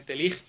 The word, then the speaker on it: Tillich: اور مختلف اس کے تلفظ کیے گئے ہیں، فارسی میں مثلا تیلیخ بھی لکھا گیا ہے اور تیلیش بھی لکھا گیا ہے، حقیقت اس کے سپیلنگ جو ہیں TILLICH ہے، پول PAUL پول تیلیچ، یعنی ہم میرے خیال میں انگلش میں تلفظ تیلیچ کریں گے اس کو، اور انہوں نے اپنا، کسی نے تیلیچ کیا ہے، کسی نے تیلیخ کیا ہے،